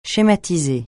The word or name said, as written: schématiser